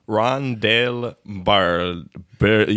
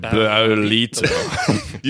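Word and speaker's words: Ron del Barrilito: Rondel Barrilito. You want to roll your R, but I can't do it. Barrilito.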